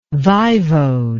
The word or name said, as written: vaivode